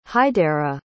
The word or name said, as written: haidera